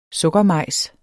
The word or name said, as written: sukkermajs